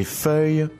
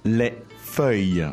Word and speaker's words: feuille: Les feuilles ont bougé. – Let's start with the word for leaves. – Les feuilles.